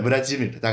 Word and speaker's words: Vladimir: 比如说，呃，我们说一个非常常见的这个俄文名字叫弗拉基米尔，也许大家知道它的拼写是 V L A D I M I R，中间的拼写是 D I，但是它不念 D 而念 G，所以是 Vladimir，怎么怎么读呢？ – 呃，弗拉基米尔大概是这样的。